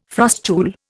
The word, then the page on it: frustule: Indian